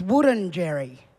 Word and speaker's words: Wurundjeri: And the proper pronunciation of it, though, it's not Wurundjeri, it's Wurundjeri. Wurun is a red gum tree. Wurun and Jerry is the little bug, the barty grub, the widgety grub in the tree. So the proper pronunciation is Wurundjeri.